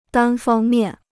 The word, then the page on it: 单方面: dān fāng miàn